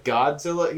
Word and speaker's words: Godzilla: He doesn't pronounce it right. He doesn't do like Godzilla. He doesn't put the emphasis on the God.